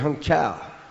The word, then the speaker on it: Hangzhou: The guides, when we came into about the second or third city that we came into in China, called it Hangzhou. That's the way they pronounce it, Hangzhou. And we noticed all the bicycles in Hung Chau.